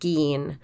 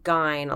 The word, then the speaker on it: Gein: It's pronounced Gein and not Gein. A lot of people pronounce it Gein. – And they'd be wrong. – They'd be wrong. And you know how you can remember? Gein rhymes with fiend. Rhymes with peen.